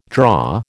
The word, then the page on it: draw: American English